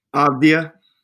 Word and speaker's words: Avdija: Avdija.